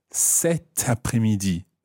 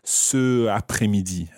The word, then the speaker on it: cet après-midi: And in that situation, you say cet après-midi. Cet après-midi. – Cet après-midi. – But cet is spelled C-E-T.